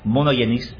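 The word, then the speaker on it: monogenes (μονογενής): There's a Greek word there. It's pronounced, let me get it right, monogenes. Monogenes. That's how you say it in Greek. The G in Greek is like a Y.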